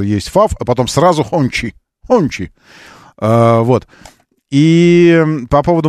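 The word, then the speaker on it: Hongqi: Правильно говорить не Хочи, а Хачи, если вы в множественном числе говорите. Вот, а если вы про автомобиль, тогда он Хончи, и это ФАВ.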